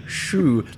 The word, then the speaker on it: Schue: To our one American listener, the reason we're pronouncing Shu like that is because it was spelled S-C-H-U-E.